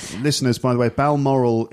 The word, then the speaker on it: Balmoral: Balmoral. – Ah, it's Balmoral.